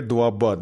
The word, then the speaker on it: ਦੁਆਬਾ: ਡੂਬਾ radio ਡੂਬਾ radio ਉਹ ਥੋੜਾ ਭਲਾ ਜੇ ਮੈਂ ਕਿਹਾ ਵੀਰੇ ਡੂਬਾ ਨਹੀਂ ਵੀਰੇ ਦੁਆਬਾ ਦੁਆਬਾ ਹਾਂ